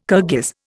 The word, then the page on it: Kirghiz: Indian